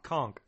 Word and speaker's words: conch: Is that right? Or is it conch? – Conch.